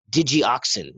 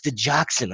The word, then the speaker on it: digoxin: And the way it's spelled, it doesn't look that way. It looks like digioxin. That's what it looks like. So all of us said it wrong 1,000 times before we were told, no, it's digoxin. Like, oh, digoxin.